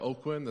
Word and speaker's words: Aucoin: This year we have Brent and Janet Oquin. That's how you say that, A-U-C-O-I-N, or so I'm told. I always butcher it, but I'm pretty sure it's Oquin.